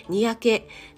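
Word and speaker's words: 若気: 若いに気持ちのきと書いて、にやけと読むそうなんですね。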